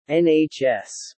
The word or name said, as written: NHS